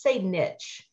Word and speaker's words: niche: And by the way, as Jason Lynette would say niche instead of niche. – Oh, I say niche. I can't bear the word niche. It sounds like knit. It does my head in. – Niche. It rhymes with rich.